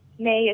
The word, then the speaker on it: Mae: And May is spelled M-A-E.